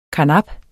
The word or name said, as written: karnap